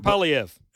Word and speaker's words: Poilievre: Pierre Poilievre.